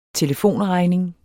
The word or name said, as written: telefonregning